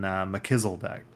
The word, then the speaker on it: Melchizedek: I've always been interested in Melchizedek.